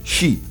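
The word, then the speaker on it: Shi (四): dato che si può pronunciare Shi allo stesso modo, cioè dell'ideogramma morte.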